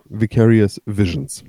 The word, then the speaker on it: Vicarious Visions: Und das Ganze von den Entwicklern äh, Vicarious Wishes. Visions. Ja, Vicarious Visions. – Mhm. – Erstmal Erstmals richtig gesagt.